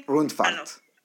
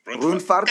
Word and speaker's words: Rundfahrt: it is called Thuringen Run something? Rundfahrt. – Rundfahrt.